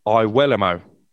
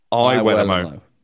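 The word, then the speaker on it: Iwelumo: Well, it was Chris Iwelamo, but I have to pull you up there because he's told me this evening the correct pronunciation of his surname is actually Chris Iwelamo. Not Iwelamo, Iwelamo.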